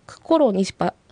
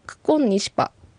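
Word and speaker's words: ククンニシパ: そうですね、ここはコロの R とニシパの頭文字の N、 RN が重なって、この場合はその前の R は N の発音になりますので、クコロニシパよりはクコンニシパと発音される方が自然と言えます。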